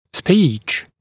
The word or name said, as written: speech